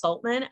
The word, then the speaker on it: Soltman: So you can simply follow my name is Michelle, that's spelled with two L's, and my last name is Soltman, S like Sam, Oltman.